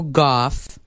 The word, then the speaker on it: Gauff: Corey Coco Gauff. G-A-U-F-F, is that how you say it? Gauff? – I think it is, yeah.